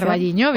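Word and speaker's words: O Carballiño: Vamos a hablar de la fiesta del pulpo, que se celebra en Ocarvalliño, no lo sé pronunciar. – Ocarvalliño, bien, exacto.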